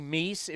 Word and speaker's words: Aledmys: As Aledmys Diaz. It's actually Meese, if you look at the pronunciation. – I know. – Guide. – I always hear Aledmys. – So yeah, it's really Meese, but Aledmys, whatever you want to call him.